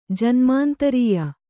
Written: ಜನ್ಮಾಂತ್ರಿಯ

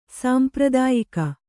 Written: ಸಾಂಪ್ರದಾಯಿಕ

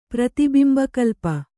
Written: ಪ್ರತಿಬಿಂಬಕಲ್ಪ

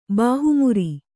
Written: ಬಾಹುಮುರಿ

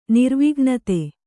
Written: ನಿರ್ವಿಘ್ನತೆ

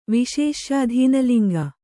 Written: ವಿಶೇಷ್ಯಾಧೀನಲಿಂಗ

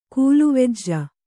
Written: ಕೂಲುವೆಜ್ಜ

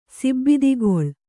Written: ಸಿಬ್ಬಿದಿಗೊಳ್

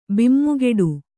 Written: ಬಿಮ್ಮುಗಿಡು

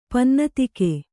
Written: ಪನ್ನತಿಕೆ

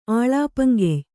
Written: ಆಲೇಪಂಗೆಯ್

